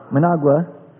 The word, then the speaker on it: Managua: Charles Royal and City Council President Norm Rice have proposed that Managua, how do you pronounce it? Managua. There we go, thank you.